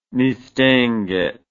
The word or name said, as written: Mistinguett